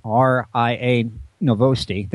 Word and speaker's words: RIA Novosti: A Russian news outlet, RIA Novosti, there, I said it right for a change, reported that scientists back in Russia have been looking at things that they're growing on the ISS.